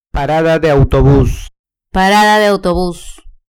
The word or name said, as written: parada de autobus